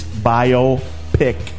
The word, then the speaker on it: biopic: It up, and sure enough, the pronunciation in the Webster's Dictionary is biopic.